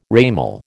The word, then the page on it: ramal: American English